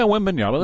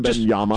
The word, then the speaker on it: Wembanyama: And Wembanyama, am I saying it correctly? – Yeah, Wembanyama. That's fine. Wembanyama.